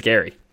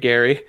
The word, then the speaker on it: Geri: So Freki is spelled F-R-E-K-I. Could easily be Freki. And Gary is spelled G-E-R-I. – Oh, yeah. – So make that what you will, but I think it's Gary.